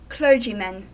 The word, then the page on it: clergymen: American English